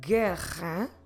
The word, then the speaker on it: guerra: For instance, if we want to pronounce guerra, guerra means war, guerra, we start with a G, we have U-E, G-U-E, and because of this, we don't pronounce the U at all. Guerra. Guerra. Como se escreve guerra? G-U-E-R-R-A.